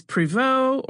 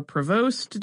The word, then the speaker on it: Prévost: And when they met, the elder Theodosia was married to Jacques Marcus Prévost, or Prévost, depending on how you pronounce it, if you're French or American.